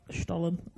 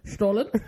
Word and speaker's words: Stollen: That's not how you say it. But our family friends are Swiss, and that's how they say it. So I'm called Stollen.